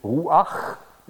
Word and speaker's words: Ruach: Wenn Sie das laut aussprechen, würde man sagen, ja, der Heilige Geist, das haben Sie vielleicht mal gehört, weht, wo er will, Ruach, ja, da haben Sie es in der Tat im Hals, dieses Geräusch,